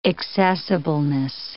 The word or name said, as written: accessibleness